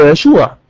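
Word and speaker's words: يشوع (ישוע): لان يسوع تنطق بالعبرية يشوع،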